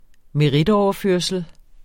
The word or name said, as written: merit